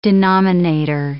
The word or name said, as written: denominator